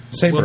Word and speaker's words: Saibra: No, it's, according to this, it's S-A-I-B-R-A. – R-A? – R-A. – Okay. – Sabra.